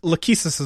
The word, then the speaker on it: Lachesis: How do you say it? – It's the Where did I get Lacasis? I don't know. Abyssalis. – Because Lachesis is one of the sisters.